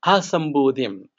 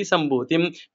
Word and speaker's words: Asambhutim: And in this mantra, the beginning should be Asambhutim and should not be Sambhutim, because the A will be silent. Because of this Sandhi rule, the A is silent or hidden.